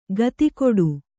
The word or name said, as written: ಖಾತಿಗೆಡು